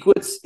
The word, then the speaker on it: Equitz: Everything is Equitz Guitars. So okay, too easy. – Equitz is a weird name, but it's spelled like equity, but with the Z instead of a Y.